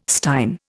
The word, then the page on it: stein: Indian